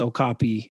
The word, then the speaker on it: Okapi: So, and the only reason I know it's Okapi, and that's how you say it specifically, is my son has a book that goes over all these different animals and it says Okapi and tells you how to pronounce it.